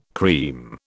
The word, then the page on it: cream: British English